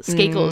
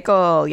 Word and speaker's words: Skakel: Skakel is how you say his last name, right? – Skakel, yes.